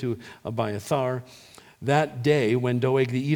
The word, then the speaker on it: Abiathar: But Abiathar, that is how you pronounce his name in the Hebrew in verse 20, Abiathar, the son of Ahimelech, son of Ahitub, escaped and fled to join David.